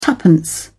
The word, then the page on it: twopence: British English